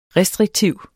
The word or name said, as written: restriktiv